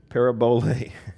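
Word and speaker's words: parabole (παραβολή): and it's P-A-R-A-B-O-L-E. Now, the transliteration or the pronunciation, how to kind of get that technically correct, it's P-A-R-A-B-O-L-A-Y. So, parable, parabolo, or parabole. Parabole. The last is a long. So, parabole. Everybody say parabole.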